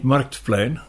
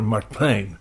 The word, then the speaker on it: Marktplein: Of dat je het accent van een zelfstandig naamwoord op de tweede lettergreep legt in plaats van op de eerste. Eh, ik heb even zo gauw geen voorbeeld voorhanden. Uh, Marktplein. Niet Marktplein, maar Marktplein.